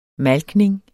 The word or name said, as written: malkning